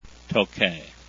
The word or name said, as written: tokay